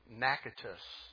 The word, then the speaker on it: Natchitoches: It's Natchitoches. It's how they pronounce it in Louisiana.